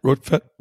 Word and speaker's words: Rortvedt: Their catcher that is now taken over from Pinto, Ben, is it Rortvedt? – Rortvedt. Yeah. Rortvedt.